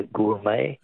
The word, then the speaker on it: gourmet: Words that end with a silent T. Beret is B-E-R-E-T. Which word for a connoisseur comes from an old French word meaning wine taster? – Be a gourmet. – Gourmet, yes. Correct, G-O-U-R-M-E-T.